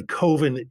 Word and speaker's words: Coven: I was both thrilled and depressed to find out that actually Coven is an acceptable alternative pronunciation.